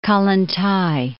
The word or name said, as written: Kollontay